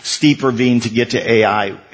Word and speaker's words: Ai: It's spelled Ai, and it's pronounced Ai.